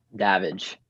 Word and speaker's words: Davidge: Davidge? – Davidge. – Yes. So what's a Davidge? – All right, you got to get it right. It's Davidge. It's like Savage with a D, but okay.